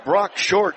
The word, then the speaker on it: Brock Short: 26-yard scoot to the end zone by Brock Short. Did I say his name properly? – Yes? You did.